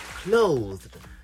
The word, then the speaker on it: clothed: Mitchell says the word is not pronounced "closed." Rather, you say clothed.